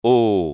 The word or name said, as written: o